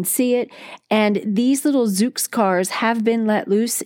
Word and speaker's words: Zoox: It is called the Zoox. And if you want to look that up online, it's spelled Z-O-O-X.